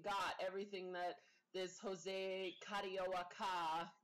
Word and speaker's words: Carioca: And when I went to like Google the pronunciation, because it's in Brazil, it's, you know, the Portuguese. That's how they pronounced it, Carioca.